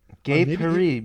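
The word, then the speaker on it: gai Paris: Gay Paris meeting. It's gay Paris, French pronunciation.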